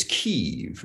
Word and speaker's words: Kyiv: Um, I was when I was in it's Kyiv now, apparently, not Kiev.